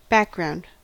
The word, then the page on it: background: American English